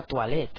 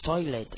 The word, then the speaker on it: toilet: Toilet, lavabo, aunque se escribe toilet y parece que sea en francés, no diremos nunca toilet, ¿ok? Es toilet, lavabo.